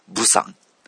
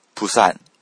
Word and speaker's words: プサン: ブサンというふうに書きます。これはですね、韓国人がこれを発音すると、プサン、プサンっていうふうに発音して、これプサンって聞こえますよね。プサン。でも、日本人が発音すると、ブサンっていうふうになっちゃうんですね。